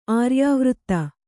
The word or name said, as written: ಆರ್ಯಾವರ್ತ